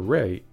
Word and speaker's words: Wray: In this case, we're talking about Attorney General Bob Barr and FBI head Christopher Wray, which is spelled W-R-A-Y, by the way, unlike Ray of Bob and Ray.